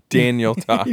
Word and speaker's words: Daniel Tosh: I honestly thought he was Daniel Tosh until I looked it up. – No, I get that. But did he have a sex change? – Wait, you said Danielle Tosh. – Daniel Tosh.